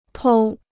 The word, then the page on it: 偷: tōu